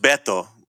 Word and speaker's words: Beto: Well, I think the mayor of Uvalde called out, and it's pronounced Beto, but Beto O'Rourke.